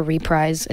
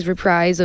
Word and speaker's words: reprise: It's like a reprise, is how you say it, reprise of Growing Pains, that I also made in my basement, but it just felt like it tied everything together.